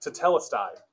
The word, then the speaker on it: tetelestai: It's a Greek word pronounced tetelestai. Tetelestai.